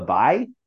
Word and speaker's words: Baie: Notre Dame De La Baye is how I'm going to pronounce it, but the Baye is B-A-I-E. So De La Baye is what I'm going with.